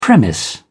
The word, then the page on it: premise: British English